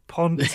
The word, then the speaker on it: Pontes: Ponce? – Pontes. – How do you spell that? – P-O-N-T-E-S, Ponce. – Pontus. Oh, Pontus. It just sounds like you're saying Ponce.